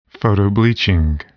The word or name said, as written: photobleaching